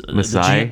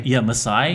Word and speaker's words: Masai: What's Masai? – Masai.